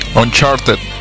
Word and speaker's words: Uncharted: ¿Uncharted o Uncharted? – Uncharted. – Uncharted, ok, Uncharted 4.